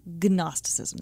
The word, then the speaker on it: Gnosticism: Gnosticism. There's a silent G at the front.